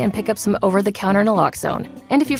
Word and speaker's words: Naloxone: For the record, it's Naloxone. Nuh, like, is Naloxone addictive? Nuh-uh. Lox, like, Naloxone keeps humans safe, like, locks keep bikes safe.